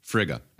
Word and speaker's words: Frigga: Frigg is Odin's wife, goddess of marriage and motherhood. Can also be pronounced Frigga.